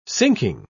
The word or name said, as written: sinking